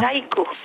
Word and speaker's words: Jaiko: Jaiko. – D'accord. – J-A-I-K-O.